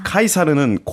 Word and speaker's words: 카이사르: 근데 이 시저는 카이사르의 영어식 발음이고 아. – 카이사르는 고대 로마 언어였던 라틴어 발음이라고 합니다.